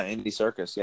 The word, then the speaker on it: Andy Serkis: Yeah, Andy Serkis.